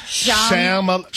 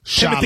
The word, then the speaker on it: Chalamet: Close. Shalomay. Timothy Chalamet.